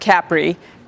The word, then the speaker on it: Capri: And yes, it is pronounced Capri. I don't know why, but it is.